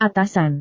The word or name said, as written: atasan